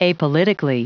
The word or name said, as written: apolitically